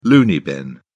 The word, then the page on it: loony bin: British English